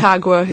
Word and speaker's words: Aconcagua: Aconcagua? – Aconcagua in Argentina.